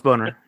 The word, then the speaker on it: Boehner: So you know the proper German pronunciation of the name Boehner, right? – Boner? Bonner? – No, it's Boner. Yeah. – Boner, okay. – It sounded better in the original German.